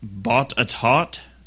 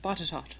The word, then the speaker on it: Botetourt: It's pronounced Botetourt. – Botetourt. – Botetourt. – That does not look like how it's spelled. – No. – We kind of have a Louisiana pronunciation going on here, is what it looks like.